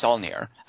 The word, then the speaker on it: Saulnier: Is that how you say his name, Saulnier? – I don't know that you put the French twist. I think it's just Saulnier.